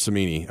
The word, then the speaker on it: Cimini: The problem is, and he's talking about Rich Cimini, I think is how you say his name. – Yeah, that's how you say it.